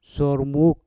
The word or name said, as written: sour muk